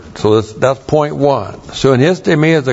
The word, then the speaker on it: sunistemi: Hotheos, the God, and then the verb sunistemi. That's S-U-N-I-S-T, long E-M-I, sunistemi.